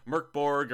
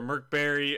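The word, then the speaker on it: Mörk Borg: If you like Merkborg or Merkberry, as I've been told it's pronounced, even though the creators say, just say Merkborg, it works.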